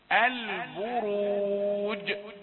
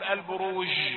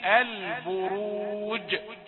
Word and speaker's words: البروج: البروج، الجيم شديدة، يعني واحد ينطق مثلا يقول البروج، يقول هذا خطأ، هذا جنوح بالجيم إلى الشين، لصفة التفشي في الشين، إذا قال البروج هكذا. لكن الجيم شديدة، البروج، هي شديدة، وهنا لأننا وقفنا عليها ساكنة مقلقلة، ذات البروج.